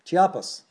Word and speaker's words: Chiapas: Tuxtla, Chiapas. There we go, Chiapas.